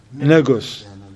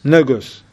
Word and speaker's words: Negus: Negus. I'm saying that right? – Negus. – Negus. I don't want it to mix it with the other one. All right, Negus. All right, mashallah. All right, Negus. N-E-G-U-S.